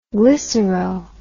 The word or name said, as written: glycero